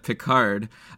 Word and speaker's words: Picard: You already corrected me just from this last segment where I said Pickard instead of Picard.